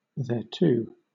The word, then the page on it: thereto: British English